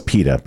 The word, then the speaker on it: PETA: Speaking of PETA, which you just did, you pronounce it PETA. – It is PETA, by the way.